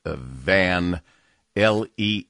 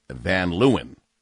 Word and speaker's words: Van Leeuwen: When you go in there and ask for it, it's pronounced, even though it's spelled Van, L-E-E-U-W-E-N, it's pronounced Van Leeuwen.